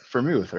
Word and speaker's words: Freiermuth: Pat Freiermuth. Freiermuth, right? Like the booze.